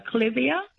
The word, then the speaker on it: clivia: So first of all, I pronounce it clivia, but it is a tomato, tomato.